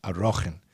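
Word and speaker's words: Arachin: We call it Erchen. But it's, I think it's grammatically properly pronounced Arochen.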